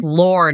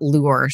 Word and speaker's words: lore: That brings up lore, not lure.